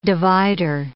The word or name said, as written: divider